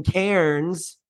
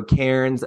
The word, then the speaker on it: Cairns: It's not that they don't pronounce their R's always. It's just like, and when it's, honestly, it's before an N, right? So Cairns, Melbourne, it's the R goes silent.